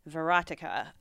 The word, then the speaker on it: Verotica: Verotica? – Yep. – All right. Spencer was like, it's like erotica, but with a V. Verotica.